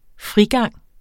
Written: frigang

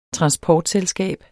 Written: transportselskab

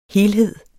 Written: helhed

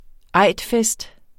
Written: eidfest

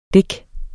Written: dik

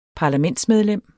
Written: parlamentsmedlem

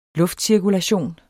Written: luftcirkulation

